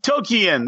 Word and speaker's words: Tolkien: No, let's channel our inner Tolkien greed. Tolkien. There you go. Get it pronounced right.